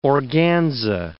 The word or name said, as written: organza